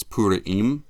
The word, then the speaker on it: Purim: If you know any kind of history, this is called, in America, we say Purim or Purim, but it's Purim.